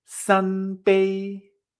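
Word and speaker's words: san pei (三杯): Por ejemplo, conductor en chino se dice fíjate, uno, uno, O si quiero decir tres tazas, el número tres es san y taza o vaso es pei. Así que tres vasos o tres tazas, san pei, san pei, tono robot.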